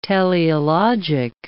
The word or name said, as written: teleologic